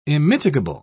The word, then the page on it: immitigable: British English